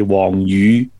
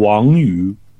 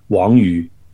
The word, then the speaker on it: Wang Yu: Well, it depends on how you want to pronounce it in Cantonese or Mandarin. Cantonese, yeah, you know, would be Wong Yu, whereas in Mandarin it would be Wang Yu. Well, you know, he's from Taiwan. Oh, well, he's actually from Shanghai. So I think we should go by the Mandarin pronunciation, which of course should be Wang Yu.